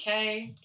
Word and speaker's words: Kay: For social media, you can find me on Facebook at Lindsay Kay, K-A-Y is spelled out. Same deal on Instagram. It's Lindsay, K-A-Y-N-Y-C.